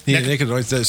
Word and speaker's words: neonicotinoids: They've been battling pesticides and CDCs and necromite. Am I say that right? Neonicotinoids. – Neonicotinoids.